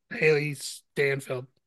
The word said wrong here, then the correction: Steinfeld: That's our young Kate Bishop, Haley Steinfeld. – Haley Steinfeld, yep. – Yeah, Steinfeld.